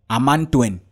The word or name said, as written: AMANTWEN